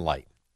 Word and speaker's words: Licht: I'm looking at his last name. It's L-I-C-H-T. Jason Licht. – Right. It's Jason Light. – Well, why isn't it spelled L-I-G-H-T? – I have no idea, Al.